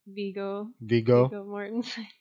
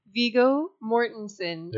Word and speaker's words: Viggo Mortensen: Viggo Mortensen. – You just said it wrong again. – I know, my brain. – Viggo Mortensen. – There you go.